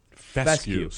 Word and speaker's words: fescue: Fescue. Spell that. – F-E-S-C-U-E. – Okay, easy. – Yeah, it's fescue.